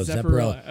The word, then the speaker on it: Zepparella: You have Zepparella at Harlow's. Did I say that right? You looked at me. – Zepparella. No, Zepparella. Okay.